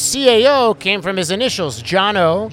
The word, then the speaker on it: Cano: His first name was Jono, C-A-N-O. Yeah, yeah. – With a J. – With a C, but in Armenian, it's pronounced Ch. So it's Jono. So spell his name. – C-A-N-O. – C-A-N-O. Jono. We would say Cano.